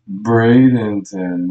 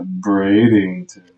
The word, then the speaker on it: Bradenton: It's Bradenton, not Bradington.